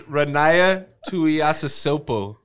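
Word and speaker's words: Ronaiah Tuiasosopo: What's her name? Ruana? – Ronaya? – Smash your pass. – It's Ronaya Tuiasosopo.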